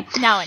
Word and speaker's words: Nouwen: You talked about Henry Nouwen. Is that how you say his name? – Yes. – I've never known how to say his name. – Nouwen. Yeah.